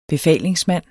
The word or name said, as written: befalingsmand